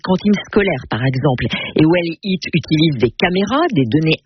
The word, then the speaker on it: WE2LEAT: Alors, près d'une semaine après la rentrée scolaire, dans l'écho d'ici, vous nous faites découvrir Well Eat. Well Eat, c'est comme ça que ça se prononce, W-E-2-L-E-A-T, Well Eat, ce qui signifie bien manger en anglais.